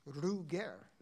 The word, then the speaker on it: Roger: This wonderful tune by the guitarist in Vessen called, the guitarist is, well, we all say Roger Tallroth, but I believe the pronunciation of his name is Ruger.